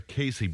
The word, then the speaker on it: Cayce: So it's Edgar Cayce, but it's spelled C-A-Y-C-E, isn't it?